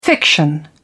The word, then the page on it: fiction: British English